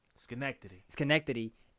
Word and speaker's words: Schenectady: On July 21, 1978, a four-day bench trial in, how you say it again? – Schenectady ended with Smith being found guilty of kidnapping,